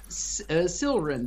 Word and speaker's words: Sylren: Silren, that's S-Y-L-R-E-N. – S-Y-L-R-E-N, Silren.